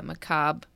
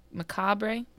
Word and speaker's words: macabre: Hold on. Let me make sure I'm saying it right. And unusual. Stories of the macabre. – Macabre. – Macabre. Mm-hmm. Okay, that's what I thought. Macabre. I thought I said it right.